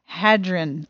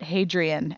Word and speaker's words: Hadron: The large Hadron Collider is a highly appropriate analogy for the speed with which some romance couples fall in love, but it's pronounced Hadron, not Hadrian, as in Hadrian's Wall.